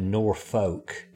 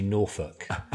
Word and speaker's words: Norfolk: Yeah, so the Norfolk Broads, Norfolk is, or as you would say in America, Norfolk, it's actually Norfolk. So just putting it out there, the correct pronunciation.